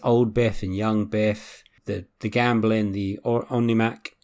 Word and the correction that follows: almanac: is that how you say it? – Almanac.